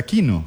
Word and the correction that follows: Keanu: Keanu. – Ah, como Keanu Reeves. – Como Keanu Reeves, pero es Keanu Chacón.